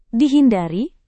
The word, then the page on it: dihindari: Indonesian